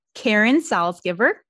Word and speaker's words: Salsgiver: She is the lovely Karen Salzgiver.